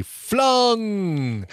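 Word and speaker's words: Fluung: And I say it like that because there's two U's, F-L-U-U-N-G, Flung.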